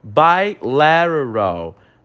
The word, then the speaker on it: bilateral: Bilateral. Bilateral é bilateral. Né? O T aí, ele tem um sentido de R, ele faz essa entonação, essa pronúncia de R. Então fica bilateral.